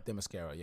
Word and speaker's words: Themyscira: Well, I can't pronounce Themyscira. – Themyscira, yeah.